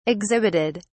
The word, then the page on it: exhibited: British English